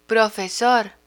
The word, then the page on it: profesor: spanish